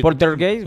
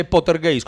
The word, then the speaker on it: Poltergeist: Usted casi dice Pottergeist, como que salía Harry Potter a asustar.